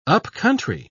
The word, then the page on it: upcountry: British English